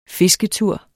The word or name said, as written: fisketur